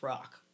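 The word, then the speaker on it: Roch: My ex-friend member, hi, E, she said Saint Rock is pronounced rock.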